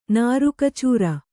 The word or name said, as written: ನರಕಚೋರ